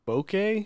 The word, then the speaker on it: Bokeh: It's called uh, uh, Bokeh. I think that's how you pronounce it. It's B O K E H.